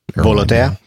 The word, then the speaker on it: Volotea: A Volotea flight. Am I saying that right? I have never pronounced that. – Volotea.